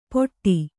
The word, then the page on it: ಪೊಟ್ಟಿ: poṭṭi